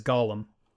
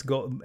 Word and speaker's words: golem: It's when I was a kid, I always pronounced it in my head as Golem. – Oh, okay. – But it's Golem.